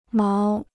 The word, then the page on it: 毛: máo